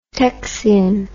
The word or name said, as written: texian